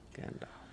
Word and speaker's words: Gandalf: And he said it rhymes with half, as in half a sixpence. – Oh, Gandalf.